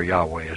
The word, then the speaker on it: Yahweh: We find in the book of Genesis the three primary names of deity: Elohim, Jehovah, or Yahweh, is probably more correctly pronounced, but Jehovah is a historical pronunciation in English, and Adonai.